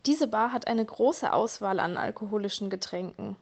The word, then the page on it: alkoholische Getränke: German